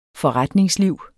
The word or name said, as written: FORRETNINGSLIV